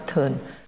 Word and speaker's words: Tson: T-S-O-N is pronounced as Turn.